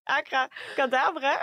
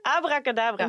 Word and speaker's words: abracadabra: Nou ja, ik kan het woord niet uitspreken. Acra cadabra. Abracadabra.